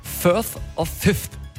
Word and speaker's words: Firth of Fifth: Der Song Firth of Fifth. Habe ich das richtig ausgesprochen? – Gutes TH.